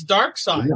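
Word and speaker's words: Darkseid: And I remember going up to Jack and asking him, was the character's name pronounced Dark Side or Dark Seed? No, it seemed a viable question. And yeah. It's Dark Side.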